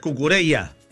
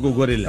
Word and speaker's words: Cucurella: No, es Cucurella, no Cucurella.